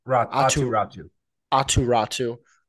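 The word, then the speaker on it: Aatu Räty: Atu Ratu. – Like Nosferatu.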